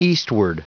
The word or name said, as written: eastward